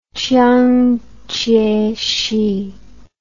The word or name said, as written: Chiang Chieh-shih